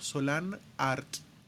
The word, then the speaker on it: Solanart: Eh, volviendo al tema, eh, hay una página web llamada Solana Art lo puedes pronunciar separado, Solan Art o Solanart.